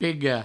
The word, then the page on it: pega: pego; provencal